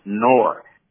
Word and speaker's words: Knorr: K-N-O-R-R. – Write it down. – It's a Swiss product, pronounced Noir. The K is silent. – Right. – But it's K-N-O-R-R.